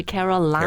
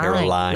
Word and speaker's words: Caroline: Oh, 这发音不是 Caroline, 是 Caroline. – Caroline. – Like a line, 一条线、对吧 C 里面、我很喜欢的名字 Camilla.Camilla Parker Bowles.